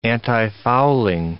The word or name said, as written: antifouling